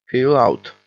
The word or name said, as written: peel out